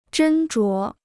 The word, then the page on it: 斟酌: zhēn zhuó